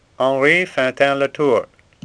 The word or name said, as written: Fantin